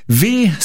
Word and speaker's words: W: Wird geschrieben Wie Social, ausgesprochen wohl W Social. Mhm. Englisch. – Oder nur W.